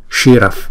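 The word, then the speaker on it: śiraḥ: thì chúng ta sẽ chuyển cái visarga này thành chữ, cái âm là cái âm f, chúng ta đọc là shiraf, shiraf, không, shiraf praname. Để cho chi, để cho chữ f này nè là chúng ta đọc bằng phần môi, thấy không?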